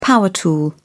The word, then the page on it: power tool: British English